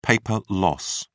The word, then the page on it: paper loss: British English